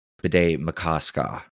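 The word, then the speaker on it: Bde Maka Ska: Oh, and if you're wondering, the lake Bede Makaska, the correct pronunciation is Bede Makaska. Pardon me. It's spelled BDE space MAKA space SKA.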